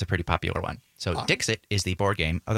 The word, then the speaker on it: Dixit: D-I-X-I-T. – Dixit. Almost like Dixieland or something. – Yeah. It's like that, but it's a T instead of an E.